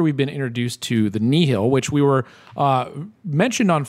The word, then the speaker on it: nihil: and you pronounce it Nihil, which is very helpful.